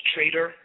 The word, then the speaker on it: Trader: words Trader, T-R-A-D-E-R, Fox, and Fox is spelled F-O-X-X.